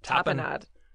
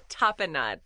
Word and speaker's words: tapenade: Am I saying it right? – Tapenade. Yes. – Nod or not? – Nod. – Top a nod. Top, that's the word. – Tapenade. T-A-P-E-N A-D-E, tapenade.